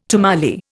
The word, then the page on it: tamale: Indian